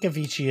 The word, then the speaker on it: Avicii: It's spelled A-V-I-C-I-I, if you didn't know.